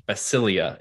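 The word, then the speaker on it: basileia: If you're interested in the original language, the word is, I'm going to put it in the chat, it's basilia is how you pronounce it. Basilia. And the means king or kingdom qualities.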